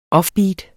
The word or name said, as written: offbeat